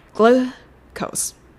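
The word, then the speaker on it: glucose: グルーコース。グルコース。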